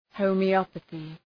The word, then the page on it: homeopathy: ,həʋmı’ɒpəɵı